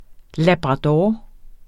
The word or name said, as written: labrador